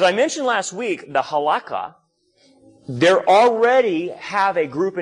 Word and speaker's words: Halakha: It's H-A-L-A. K-H-A. H-A-L-A-K-H-A.